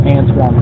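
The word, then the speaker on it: Antwon: And on Facebook, I go by my name, which is Antoine Carter. So that's A-N-T-W-O-N, last name Carter, C-A-R-T-E-R, on Facebook.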